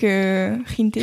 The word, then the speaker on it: Jinte: Yeah, bah, j'imagine que ça se prononce comme ça, c'est J-I-N-T-E, donc Rinte, je pense.